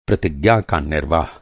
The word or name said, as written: निर्वाह